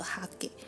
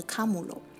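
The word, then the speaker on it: 禿: 虽然汉字写作秃头的秃，但是发音不是秃头的哈给，而是卡姆罗，是日本古代小女生留的发型，就是长度差不多齐齐到肩膀，然后像小丸子头一样的平刘海，那种发型叫做秃，日文念作卡姆罗。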